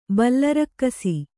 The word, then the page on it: ಬಲರಕ್ಕಸಿ: bala rakkasi